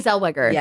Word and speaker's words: Zellweger: Zellweger? Is that how you say it? – Yes, Renee Zellweger.